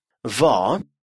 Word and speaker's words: vad: Vad. Что. Vad. Обратите внимание, что финальное «d» не читается.